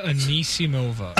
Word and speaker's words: Anisimova: How do I say it again? – Amanda Anisimova.